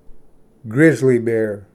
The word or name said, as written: ᏲᎾ ᎡᏆ